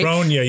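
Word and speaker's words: Brogna: I hope I'm pronouncing his name right. – Bronya, yes.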